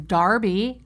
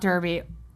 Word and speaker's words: Derby: I'm so glad that Ella's first horse race was the Epsom Derby, because that's how you pronounce it in England. Not Derby, was the Epsom Derby and not the Kentucky Derby.